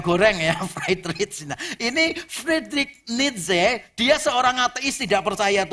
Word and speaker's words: Friedrich Nietzsche: Ini bacanya Friedrich Nietzsche, orang Jerman. Jadi ini jangan dibaca pakai bahasa Inggris ya. Kalau dibaca pakai bahasa Inggris, nama depannya agak lucu. Friedrich.